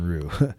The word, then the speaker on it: Ryu: You can definitely see the signage from the karate school, Shoshin Ryu, and it's spelled R-Y-U. So I know it doesn't sound like Rue, but Shoshin Rue.